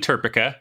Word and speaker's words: TERPECA: I was absolutely hoping you were going to ask about that because, yes, it is absolutely TERPECA, like Replica or Seneca.